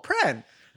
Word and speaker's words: Prahran: He's like, oh, Pran. I'm like, there's another R. R you ignored. – Like, yeah, no. – How do you have a silent R? – Wow.